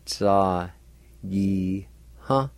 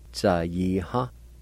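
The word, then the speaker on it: tsayiha: Word number one, brassy, pronounced like this, tsa yi ha, tsa yi ha, tsa yi ha.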